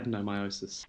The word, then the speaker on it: adenomyosis: So I'm assuming that a number of my listeners won't have heard about, which is called adenomyosis. Have I said it right? – That's perfect. Correct. Yeah, adenomyosis.